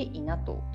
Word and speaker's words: 稲藤: ぜひぜひ、はい、皆様ですね、日永うちわもしくは株式会社稲藤というのが、稲穂の稲に富士の花の富士ですね、はい、と書いて稲藤というふうになりますので、ぜひぜひご覧いただければと思います。はい、ではですね、一番最後になるんですけれども、私が株式会社稲藤のマイエシカルはというふうに言いますので、紙に書いている内容を読み上げていただいてもよろしいでしょうか。